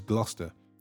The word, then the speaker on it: Gloucester: Gloucestershire is Gloucester.